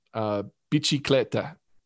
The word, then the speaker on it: bicicletta: So my favorite word is the Italian word for bicycle, bicicletta. Mm. I always really liked the way you pronounced C-I in Italian as chi.